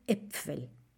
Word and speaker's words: Äpfel: Ese Äpfel se escribe, es una A al principio, pero con diéresis, con los dos puntitos arriba. Por eso se pronuncia de otra manera. Ein Apfel, zwei Äpfel.